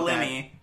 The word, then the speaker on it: Linney: Laura Linney. – That. – Is that how you say her name? – Yeah.